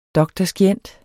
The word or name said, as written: dr.scient.